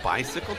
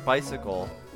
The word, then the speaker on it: bicycle: Why is the word bicycle pronounced like sickle, however the word unicycle and motorcycle are pronounced cycle?